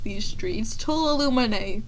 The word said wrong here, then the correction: Tuolumne: Okay. Tuolumne. Tuolumne. At uh,